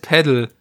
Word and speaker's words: Padel: Nein, das heißt Paddel. Ja, wie schreibt man das? P-A? P-A-D-E-L.